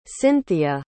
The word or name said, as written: cinthia